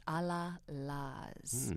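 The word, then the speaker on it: Allah-Las: A-L-L-A-H hyphen L-A-S. A La La's.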